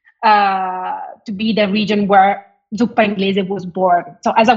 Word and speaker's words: zuppa inglese: And the one thing I want you to go back to and tell a little bit of the differences is with zuppa inglese.